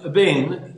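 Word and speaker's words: Ven (אבן): Ok, la parola eben, eh, non si pronuncia eben, ma si pronuncia Ven, ben con, con, la, con la, A silenziosa,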